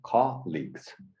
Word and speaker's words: colleagues: So not colleagues, but with the intonation on the first one, colleagues.